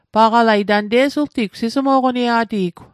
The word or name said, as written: sismo'qoniet